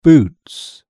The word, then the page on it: boots: British English